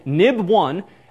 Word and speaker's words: XIB: Main window.nib, so XIB, but pronounces nib these days.